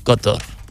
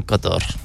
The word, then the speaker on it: Qatar: I always argue about the pronunciation, commonly referred to as Qatar. I refer to it as Qatar. Qatar, yeah. Um, correct pronunciation, I believe, is Qatar.